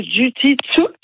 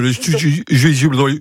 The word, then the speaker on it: jiu-jitsu: Alors, animation de jiu-jitsu, j'espère que je le dis bien. – Oui, c'était parfait, le jiu-jitsu.